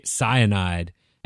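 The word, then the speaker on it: Scienide: And that name is spelled S-C-I-E-N-I-D-E. I didn't quite get it when I first looked at the word because it's spelled a little funny. And when you sound it out in your head, you're like, oh, that's cyanide. I get it.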